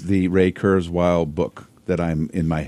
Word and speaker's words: Ray Kurzweil: Let me go Ray Kurzweil on you.